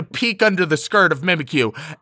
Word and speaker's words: Mimikyu: They introduced Mimikyu, Sorry, don't you mean Mimikyu? – Oh, yeah, Mimikyu, which is bullshit pronunciation.